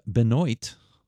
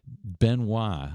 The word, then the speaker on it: Benoit: Looks like Tab Benoit, but it's pronounced Benoit.